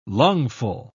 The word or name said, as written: lungful